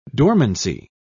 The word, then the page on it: dormancy: British English